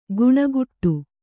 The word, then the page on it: ಗುಣಗುಟ್ಟು: guṇaguṭṭu